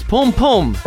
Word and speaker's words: Pum Pum: Apparently, after listening to the words and them singing it, it's Pum Pum. Alan Fong. – Pum Pum or Boom Boom? – Pum, P-U-M. – Boom. – It's spelled P-U-M-P-U-M. Two words.